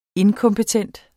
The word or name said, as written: inkompetent